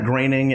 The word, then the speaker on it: Groening: It's Matt Groening and Walter Koenig from Star Trek. – Yes, exactly. – And until I tell them that there's actual evidence of people out there who pronounce O-E as A, they don't believe me.